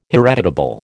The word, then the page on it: hereditable: American English